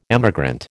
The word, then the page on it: emigrant: American English